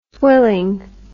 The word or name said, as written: swilling